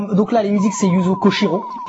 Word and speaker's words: Yuzo Koshiro: Yuzo Koshiro. Exact. Est-ce que je l'ai bien dit? Je l'ai bien prononcé. – Tu l'as très bien prononcé.